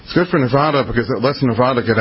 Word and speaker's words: Nevada: Nevada, I got to say it right, Nevada. They always get mad at me in Nevada if I say Nevada. It's always attaboy, Nevada, Nevada. There we go. – Nevada.